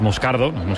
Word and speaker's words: Moscardó: Entonces, sí, como bien ha pronunciado Unai, es Moscardó, es brasileño, sí.